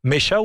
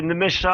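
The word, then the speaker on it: Nmecha: Félix Nuech, Mecha ou Nemecha, comment on prononce? – Oh, c'est tout en une seule, enfin tout d'un seul coup, c'est Nemecha.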